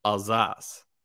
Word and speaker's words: Alsace: Or I'll say, I don't even know how they pronounce it in American, but I looked up how to pronounce it in French, and it's Alsace.